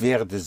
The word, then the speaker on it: werde: There are two syllables, but you only stress the first one here. – Werde. – Was more? – Ich werde. – But I didn't hear the second syllable.